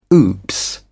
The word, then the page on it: oops: British English